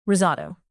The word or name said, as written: risotto